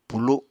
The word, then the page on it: pulu: apatani